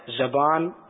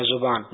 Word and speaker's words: زبان: پیارے امیر علیہ سنت کی بارگاہ میں میرا یہ سوال عرض ہے کہ صحیح تلفظ کیا ہے، زبان یا زبان؟ جزاک اللہ خیرا. – یہ دونوں طرح کی لغت ہیں، اس میں زبان زا پر زبر بھی ہے اور زا پر پیش بھی ہے، دونوں کو درست کہنا چاہیے.